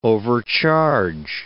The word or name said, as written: overcharge